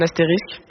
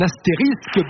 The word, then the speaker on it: astérisque: Vous hésitiez sur la prononciation, parce qu'il ne faut pas confondre évidemment avec les personnages de bande dessinée, mais vous l'avez dit, tu peux confirmer que c'est un astérisque. – Un astérisque, bonne réponse.